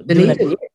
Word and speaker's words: Dunedin: Now I know you moved to Dunedin. – Dunedin.